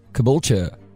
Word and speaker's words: Caboolture: Caboolture. – And that's C-A-B-O-O-L-T-U-R-E? – Correct, yes.